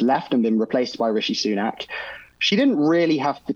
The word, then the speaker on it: Rishi Sunak: Is it Rishi Sunak? Is that correct? – Yeah, Rishi Sunak, yeah.